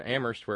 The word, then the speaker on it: Amherst: Thank you to Terry in Amherst, where only the H is silent.